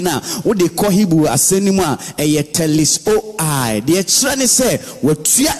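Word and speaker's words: TETELESTAI (τετέλεσται): The word or the sentence, it is finished in this statement or this scripture, is from the word TETELESTAI, which is pronounced TETELESTAI, but that is not how it is spelled.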